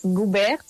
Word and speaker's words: Goubert: entonces Goubert se pronuncia, pero se escribe G-O-U-B-E-R-T, y en redes y en Spotify, en todo eso me pueden encontrar como Manuela Sánchez Goubert.